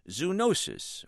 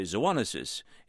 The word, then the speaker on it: zoonosis: My vocabulary word for the week is zoonosis, or some say zoonosis. In any case, it's spelled Z-O-O-N-O-S-I-S, zoonosis.